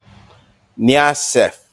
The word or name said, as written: Kniaseff